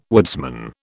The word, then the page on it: woodsman: British English